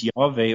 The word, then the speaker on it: Yahweh: Directly pronounced as Yahweh or as Yod Vav Yod.